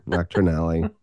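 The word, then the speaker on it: Knockturn Alley: Sorry, I said nocturnal. Nocturnally. I knew I was mispronouncing it the moment it came out of my mouth.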